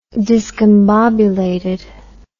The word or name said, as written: discombobulated